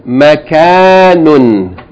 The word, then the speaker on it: makanun: Makanun. Makanun. Makanun. Short vowel, long vowel, Tanween, Makanun. Last word, line 1.